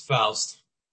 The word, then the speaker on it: Faust: I can't hear you're Yeah, it's Faust. – Faust, okay. – Rhymes with joust.